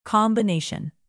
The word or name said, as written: combination